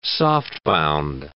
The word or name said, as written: softbound